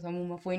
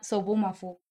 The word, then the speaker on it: Sobumafu: bueno, el chiste es que no se dice Sabumafu, ni siquiera se escribe con A. Se llama Sobumafu, o sea, con O. Y cuando lo busqué fue como que, no, la canción dice Sabumafu, pero realmente dice Sobumafu.